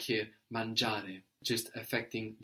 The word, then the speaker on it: mangiare: This is spelled M-A-N-G-I-A-R-E. And the I is not really pronounced here, is it? Mangiare. It's actually just giving us a soft G as opposed to a hard G. We've seen this before. Giorno, the word for day, which is spelled G-I-O-R-N-O.